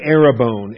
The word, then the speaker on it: arrabon: The noun Arabone, A-R-R-A-B-O-N, the long O, the omega O, Arabone, Strong's number 728.